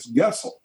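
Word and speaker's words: Goessel: The Germans would pronounce it as Gessel.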